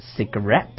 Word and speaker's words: cigarette: 比如说你要来根烟吗？Would you like a cigarette？ – 啊，大家这个单词稍微注意一下，它的发音是 cigarette。